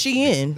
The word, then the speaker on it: Shein: It's Shein because everybody pronouncing it wrong.